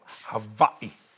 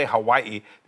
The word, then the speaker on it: Hawaii: I love the people of Hawaii. – Right. – They told me how to say it. They don't say Hawaii. They say Hawaii, Lanai, Koholawe.